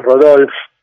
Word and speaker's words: Rodolphe: Y entonces hablaba francés, de hecho es Rodolphe, si no sería Rudolf. El, apellido es alemán, así que se debe pronunciar con dieres y Tepfer, creo.